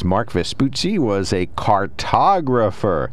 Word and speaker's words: Vespucci: Vespucci, am I saying it right? – Amerigo Vespucci. – Vespucci, yes.